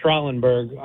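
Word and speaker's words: Schraalenburgh: It's pronounced Schrallenberg.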